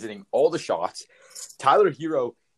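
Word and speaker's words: Herro: Did you not see Tyler Harrow? I mean, Hero. Pronounce his name right first.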